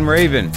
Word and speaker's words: Rabin: Nathan Rabin, am I pronouncing it right? – Yes, very much so.